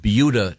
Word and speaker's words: Buda: we have yet to get to sports, but we were discussing a very interesting news item from Beulah, Texas. – Mm-hmm. – They what you insist on saying. – No, that's how it's pronounced. – Okay. – Well, it B-U-D-A?